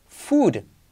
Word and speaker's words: food: ¿Cómo se pronuncia comida? Doble O. Food. Con una U. Food. U. Fu, como Fu Manchu.